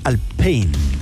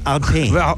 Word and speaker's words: Alpine: pero lo dicen mal porque la pronunciación real es más Alpine. No como Alpine. – Claro. – No, Alpine, no es anglo. – No, es francés, po.